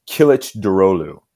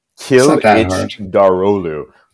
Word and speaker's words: Kılıçdaroğlu: First name is Kemal. Last name is Kılıçdaroğlu. Kılıçdaroğlu. – It's not that hard.